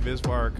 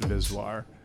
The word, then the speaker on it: VSWR: Yeah, so the name Visvar comes from a phonetic pronunciation of an acronym, VSWR. It's an electrical type of word. So when you say it, it's just Visvar.